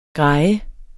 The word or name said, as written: grejer